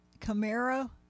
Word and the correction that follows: Kamario: Is it Camaro? No. Taylor? – I was thinking to myself, I don't even know who you're talking about. Kamario Taylor. – Kamario Taylor, yes.